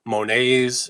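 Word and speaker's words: Monet: Monets are in there. Renoir. All these famous artists that don't pronounce the T at the end of their name? – Right.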